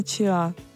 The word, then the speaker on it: чиа: К пшенице не относится, но я сегодня прочитала, что правильно говорить не семена чиа, а семена чиа.